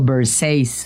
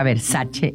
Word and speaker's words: Versace: Por ejemplo, Versace, cuando la marca Versace es italiana. O sea, tiene que pronunciar Versace.